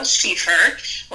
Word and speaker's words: Schieffer: My name is Chantel Schieffer. Last name is like sheaf of wheat.